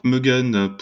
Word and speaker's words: Moegen: Donc Muggen pour euh, les personnes qui nous écoutent, ça s'écrit M-O-E-G-E-N. C'est bien ça C'est ça.